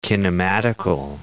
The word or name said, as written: kinematical